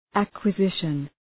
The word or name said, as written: acquisition